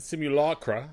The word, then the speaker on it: Simulacra: I've been playing a game called Simulacra. I think I've said that right. – I think you have. – And Simulacra, I'll say that again.